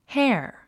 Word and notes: hare: héə r; ヘア

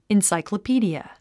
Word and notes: encyclopedia: insàikləpíːdiə; インサイクロピーディア

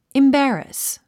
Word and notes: embarrass: imbǽrəs; インバァラァス

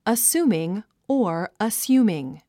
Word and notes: assuming: əsúːmiŋ; アッスゥミン

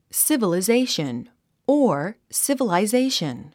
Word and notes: civilization: sìvəlizéiʃən; スィバリゼイション

